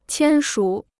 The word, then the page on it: 签署: qiān shǔ